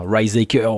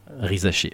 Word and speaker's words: Risacher: Tu vois, parce que ça peut être tentant pour un Américain s'ils sont pas renseignés de faire un Rizaker ou un truc comme ça. Adam Silver a très bien fait Rizaché.